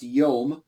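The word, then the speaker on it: yom (יום): It's pronounced yom.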